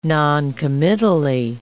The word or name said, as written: noncommittally